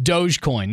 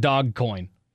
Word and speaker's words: Dogecoin: It's Dogecoin, not Dogcoin.